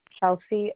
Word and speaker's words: Chelsea: Chelsea Odegaard.